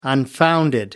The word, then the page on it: unfounded: American English